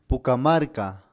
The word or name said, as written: Pukamarka